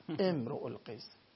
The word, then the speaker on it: امرؤالقیس: بعد میگه مادرش رباب هست بنت آقای سلیمانی امیری، امرؤالقیس، نه امرؤالقیس، امرؤالقیس. تلفظش این متن معمولی عادی هم ایشون بلد نیست بخونه.